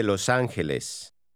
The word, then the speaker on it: Los Ángeles: Soy de Los Ángeles.